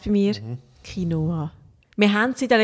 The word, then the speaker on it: Quinoa: Auf Französisch würde man sagen Quinoa. Es ist wirklich ein Moi am Schluss.